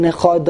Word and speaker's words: Echad: But the dalet in Echad does not have a dot. And therefore the real way to pronounce the dalet is Echad. It's a TH.